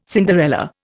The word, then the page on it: Cinderella: Indian